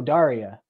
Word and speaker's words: Daria: Will Dura. – No, Daria. There's only one way. There's Daria. Daria. Daria. Like the MTV show.